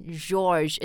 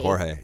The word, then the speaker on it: Jorge: 就比如 Spanish 一个 name, J-O-R-G-E.、Mm. It's not George, it's Jorge. – Jorge, yeah, yeah exactly.